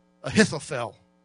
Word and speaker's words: Ahithophel: It's a man named Ahithophel, and that is the correct spelling and pronunciation of his name. Ahithophel.